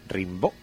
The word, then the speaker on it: Rimbaud: Ponemos con acento en la O, Rimbaud.